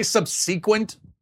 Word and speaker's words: subsequent: Subsequent. That's the way I pronounce it. Every time I say subsequent, I get emails. You say subsequent? It's subsequent. No, I say subsequent, and that's perfectly valid. Look it up.